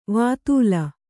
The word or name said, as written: ವೇತಾಲ